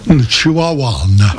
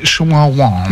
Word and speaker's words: Chihuahuan: And he said, well, if you have, in his email this is, if you have, well, that's how you pronounce the desert. It's the Chihuahua Desert. – Chihuahuan. – Oh, is it Chihuahuan? – Yeah.